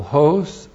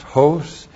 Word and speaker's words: hos: And then we have the comparative particle hos. H long O-S, hos.